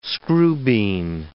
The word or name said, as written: screwbean